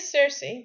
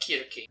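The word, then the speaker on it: Circe: It's a soft C in English, but it's written with a K in Greek. – Yeah, in classical Greek it's like Kirki.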